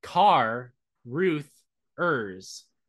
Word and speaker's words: Carruthers: It's spelled Car Ruth Ers. – Yeah, that's Carruthers. Yeah, Carruthers, yeah, weird watches UFC on his couch.